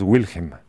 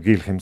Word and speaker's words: Wilhelm: Creo que eso no se debe pronunciar es Wilhelm, no Gilhelm, sino Wilhelm. Wilhelm, sí.